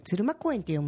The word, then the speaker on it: 鶴間公園: 鶴間と鶴が舞うと書いて鶴間公園って読むんですね。